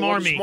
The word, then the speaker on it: smarmy: Swarmy people, swarky people. – Smarmy. – Smarmy. Smarmy. – How would you spell that? – S M A R M Y. Smarmy.